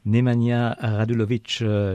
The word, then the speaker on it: Nemanja Radulović: Eh bien, le très grand plaisir d'accueillir sur les ondes de radio SBS Nemanja Radulović.